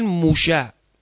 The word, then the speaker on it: موشه: یهودی ها سین ندارن، سین رو شین تلفظ میکنن. موسا رو میگن موشه.